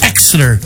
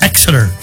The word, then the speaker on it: Exeter: That's Exeter. Like, start with the X. Even though it's spelled E, I think the E's silent. Uh-huh. It's Exeter.